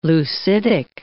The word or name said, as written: leucitic